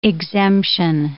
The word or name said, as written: exemption